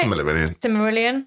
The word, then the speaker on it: Silmarillion: Silmarillion. – Yeah. – Am I saying that right? – Silmarillion.